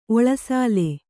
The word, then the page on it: ಒಳಸಾಲೆ: oḷasāle